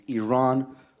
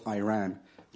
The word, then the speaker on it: Iran: Our country is named Iran, not Iran.